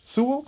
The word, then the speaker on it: Sewell: Rufus Sewell? Sewell? – Sewell, I think it is pronounced, yeah.